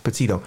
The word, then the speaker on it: Petito: Petito.